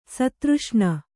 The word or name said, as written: ಸ್ಥಿರೋಷ್ಣ